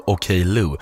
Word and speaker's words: Okello: Okello, säger man så? Ja, det står det i uttalsguiden i alla fall. – Ja, men perfekt. – Franskt uttal av Okello.